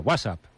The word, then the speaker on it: WhatsApp: No, peor aún, con acento en la primera. WhatsApp, ¿no? – WhatsApp.